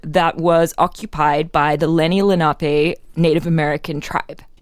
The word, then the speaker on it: Lenni Lenape: I think that's right. Lenni Lenape. – Lenape. – Yeah. – Is what Wikipedia says.